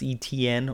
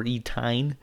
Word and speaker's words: Etienne: Travis Etienne, or Etine, as he likes to so eloquently refer to him. – If it's Etienne, just spell it with an E-T and an N.